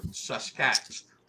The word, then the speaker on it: Saskats: They used the term Saskats. The best way to pronounce it was take the word basket, drop the B in that S-A-S.